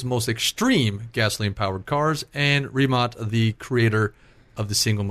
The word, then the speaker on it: Rimac: So, not to mistaken, if anybody ever watched this on any British TV, they were pronouncing it Rimac. Rimac, but it's Rimac. – Well, the problem is Croatian. We're probably not even pronouncing it right. But from what we've looked up and heard, Rimac is, I think, close.